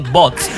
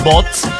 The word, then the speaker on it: Botz: Se escribe así, Bots, B-O-T-X, Z, perdón, B-O-T-Z, Bots, que es Bésame Otra Vez, básicamente resumida, pues.